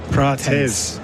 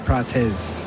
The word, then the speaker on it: Prates: Proteus. – Prates.